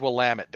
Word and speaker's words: Willamette: Also, I lived not too far from that particular town, and I have to give you the mnemonic device for how to correctly pronounce it. – Okay. – It's Willamette, damn it.